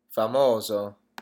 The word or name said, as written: famoso